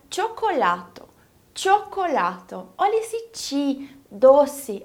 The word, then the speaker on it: cioccolato: Esse co vai ser pronunciado igualzinho como em português. Olha para a palavra chocolate, que é a própria tradução de cioccolato. Esse co do chocolate está sendo falado da mesma forma como em cioccolato.